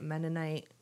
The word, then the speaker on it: Mennonite: And she was, am I saying it right? Mennonite. – Yeah.